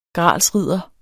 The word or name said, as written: gralsridder